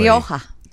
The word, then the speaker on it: Rioja: Rioja.